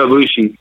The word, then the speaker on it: Ibushi: Why don't you talk us a little bit about Nakamura versus Ibushi. Did I say that right? – Kota Ibushi.